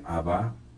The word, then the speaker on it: Abba: Y la palabra Padre Nuestro, que en castellano suena un poquito serio, cuando Jesús la pronunció en arameo, se dice Abba.